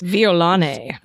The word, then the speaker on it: violone: violon? Pronounced let me get this. – Violone.